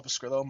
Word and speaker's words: Pescrillo: Anyways, you can find me on Twitter at Paul Pescrillo.